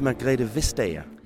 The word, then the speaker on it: Margrethe Vestager: How do you say it? – It's Margrethe Vestager.